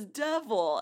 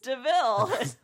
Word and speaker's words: Deville: When the car pulls up and he's like, hey, this license plate says Devil. And the guy goes, no, it's pronounced Deville.